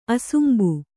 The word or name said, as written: ಅಸುಂಬು